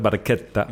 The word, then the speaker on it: Barchetta: Bueno, vamos a al siguiente tema mal pronunciado, Red Barchetta, que es Red Barchetta, el barquito rojo.